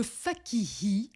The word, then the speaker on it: Fakihi: Là, Oui, mais bravo, hein, parce que le Fakihi, souvent, les gens vont soit le prononcer comme Fakini, Fakiri, donc pauvre lettre N, parce que cette lettre, apparemment, est difficile à prononcer pour certains, mais tu prononces très, très bien mon nom.